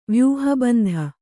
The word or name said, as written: ವ್ಯೂಹಬಂಧ